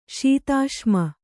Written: ಶೀತಾಶ್ಮ

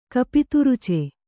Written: ಕಪಿತುರುಚೆ

